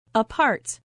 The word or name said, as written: apart